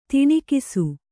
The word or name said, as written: ತನಿಖಿಸು